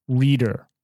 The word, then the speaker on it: Rieder: Um, so it's spelt Dylan D Y L A N reader. R I E D E R.